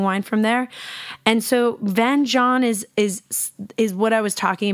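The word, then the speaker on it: vin jaune: So if Van John is the heart, beating heart of the Jura, I'm going to tell you what the hell Van John is. So it's spelled V-I-N, new word, J-A-U-N, and it translates to yellow wine.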